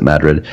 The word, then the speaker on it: Madrid: So last year we were on vacation in New Mexico, and I found a little town called Madrid, which is spelled like Madrid, but they pronounce it Madrid.